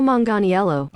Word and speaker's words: Manganiello: Joe Manganiello. – Oh, Manganiello. – Stop it. Okay, I was not even close. – Joe Manganiello.